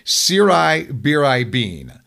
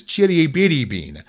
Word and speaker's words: Ciribiribin: So we went to the internet, and the American pronunciation is Siri Biri Bean. And the Italian pronunciation is Chitty Bitty Bean. You need to have that hard R in there.